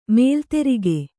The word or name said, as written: ಮಲೆದುರ್ಗ